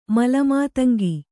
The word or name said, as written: ಮಲಮಾತಂಗಿ